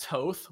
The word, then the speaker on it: Thoth: he was saying it's pronounced Toth, which I guess, sure, maybe, but like, it's Thoth. Like, Thoth is, it's, I think Thoth sounds cooler.